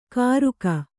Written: ಕಾರುಕ

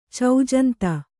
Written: ಚೌಜಂತ

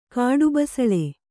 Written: ಕಾಡುಬಸಳೆ

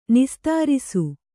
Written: ನಿಸ್ತರಿಸು

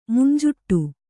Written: ಮಂಜಿಟಿ

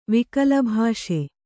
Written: ವಿಕಲಭಾಷೆ

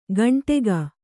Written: ಗಂಟೆಗ

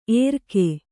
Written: ಎರ್ಕೆ

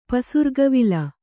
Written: ಪಸುರ್ಗವಿಲ

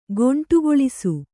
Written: ಗೊಂಟುಗೊಳಿಸು

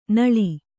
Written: ನೋಳ್

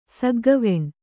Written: ಸಗ್ಗವೆಣ್

